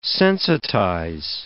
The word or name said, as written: sensitize